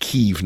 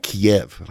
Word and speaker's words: Kyiv: So this is hitting near the capital of Kyiv, which, by the way, I've learned from my Ukraine friends that it's pronounced Kyiv, not Kiev, how the Russians pronounce it.